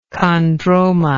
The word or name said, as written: chondroma